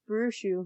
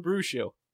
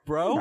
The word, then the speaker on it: Bruxo: I think Bruxo. – Bruxo. Bro? It's not bro? – I think it's brew because there's 2 O's. – No, you're right.